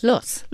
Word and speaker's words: Luss: How do you pronounce it, Luss? – Luss. – Luss, yeah.